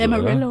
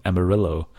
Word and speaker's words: Amarillo: Amarillo oder so. – Amarillo. – Oder? Ja. – Ja, genau. – Ich fand es gerade gut, wie ich es ausgesprochen habe. Amarillo.